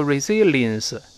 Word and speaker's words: resilience: 好，这个要点呢就是中间那个 l。一定不能勾，不要读 resilience，读成 lins，立刻这洋味就出不来了。还是李老师说的要领，舌头的前部跟上颚贴上去，resilience 是这么一个声音啊。再来一遍，resilience。好，咱们看什么叫 resilience 呢？